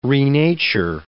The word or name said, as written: renature